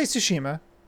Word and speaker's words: Tsushima: How do I pronounce that T? – Okay, it's not. It's not pronounced. It's like tsunami. – Tsushima. – Tsushima. – You just say Tsushima. Like sushi. But now hit the S like Tsushima.